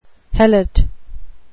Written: helot

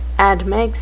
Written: admix

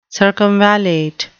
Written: circumvallate

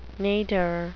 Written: nadir

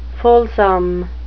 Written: fulsome

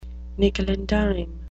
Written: nickel-and-dime

